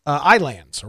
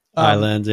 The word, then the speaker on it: Ylands: and Islands or Ylands, however you'd like to pronounce it. – Ylands, yeah.